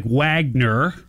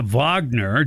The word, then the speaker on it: Wagner: So this mercenary group that works on behalf of Putin, this Wagner group, it looks like Wagner. – Right. – But it's pronounced a Wagner, German, right?